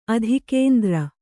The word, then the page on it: ಅಧೋಗಂತೃ: adhōgantř